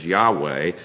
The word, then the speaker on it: Yahweh: And the consonants are what we would transliterate as YHWH, and the W can also be pronounced like a V, so YHVH, Yahweh or Yahweh.